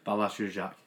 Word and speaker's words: Balázs Dzsudzsák: I don't know his first name, but his, his surname's Jujash. He's Hungarian, he's the captain. Oh, yeah, yeah. – Balázs Dzsudzsák. – There you go, yeah, that's the guy. Great pronunciation. Thank you.